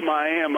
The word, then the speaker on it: Miami: And then this young lady over here a while ago was telling me I was pronouncing Miami wrong. It's Miami.